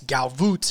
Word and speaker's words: Gavottes: But yes, Galvoots, it's spelled G-A-V-O-T-T-E-S.